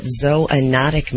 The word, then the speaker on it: zoonotic: Are you pronouncing that right? – Zoonotic. You made me go look it up. It's correct.